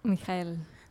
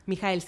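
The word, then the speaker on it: Michael: Mijael. Mijael, sí, acá Es la pronunciación. – Es la pronunciación alemana.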